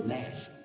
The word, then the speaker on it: gnashing: William God pulled me aside and said, young seminarian, that word is gnashing. The G is silent.